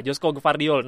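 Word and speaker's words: Joško Gvardiol: Gvardiol nggak tahu Gvardiol ya Josko Gvardiol Josko Gvardiol oh ya gitu ya Josko Gvardiol nah ini gua ing karena gua main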